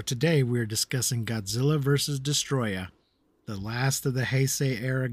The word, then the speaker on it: Destoroyah: Because in the movie, it's subtitled as Destroyer, and they just pronounce it in Japanese as Destoroyah.